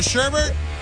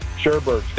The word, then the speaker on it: sherbet: There's only one pronunciation. Sherbert. – Thank you. – So you're just adding R's. – Appreciate you. No, it's just the way we say it. – It's not spelled that way.